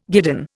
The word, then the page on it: guidon: Indian